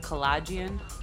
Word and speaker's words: Kalajian: My name is Andrea Kalajian, K-A-L-A-J-I-A-N. I know a lot of people have a hard time pronouncing the last name. I've been saying collision this whole time. Well, it's collagen. – Okay, so to be honest, my grandma, she taught us how to say our name wrong.